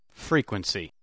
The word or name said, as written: frequency